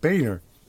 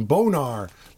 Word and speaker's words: Boehner: At least he pronounces it Boehner. This guy could pronounce his name Bonar, like sonar or something, but no.